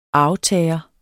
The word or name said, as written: arvtager